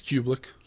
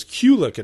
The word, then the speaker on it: Kubelik: I think it's Kubelik. I thought it was Kulik at first because they would say her name so fast.